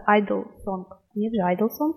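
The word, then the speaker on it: (G)I-DLE: Корейский вариант будет Idol или полный его произносит Yeo-G-Idol. Английский вариант как раз это G-Idol. Нет ничего страшного, если будут произносить люди тот или иной вариант, так как они все являются правильными. Однако сами девочки прочитают вариант без приставки, просто Idol.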